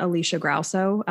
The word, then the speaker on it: Alisha Grauso: Um, I'm on there probably too much, so you can find me at Alisha Grauso, um, and that's a weird name, so I'll spell it: uh, A L I S H A G R A U S O.